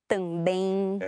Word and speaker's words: também: And then finally, words that are spelled with an E-M, those are actually diphthongs as well. And so we have words, for example, like... – Bem, porém... Também. – Excellent. And those are actually nasal diphthongs, even though it just looks like one vowel there.